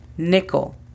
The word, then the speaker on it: nickel: La pronunciación fonética es tal y como se escucha, P-E-N-I, penny. Después tenemos la moneda de 5 centavos. Aquí es conocida como nickel. La pronunciación fonética de esta es N-I-K-L. Nickel.